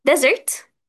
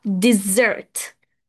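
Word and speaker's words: dessert: أول حاجة يا شباب، عندنا كلمتين بنتلخبط فيهم خلاص، ديزرت الصحراء، والديزرت اللي هي الحلوى. فخلينا نفرق بين النطق. الحلوى بتتنطق ديزرت ديزرت ديزرت خلاص، أما الصحراء بتتنطق ديزرت ديزرت. في فرق كبير قوي ما بين الاتنين.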